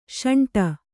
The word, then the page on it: ಶಂಟ: śaṇṭa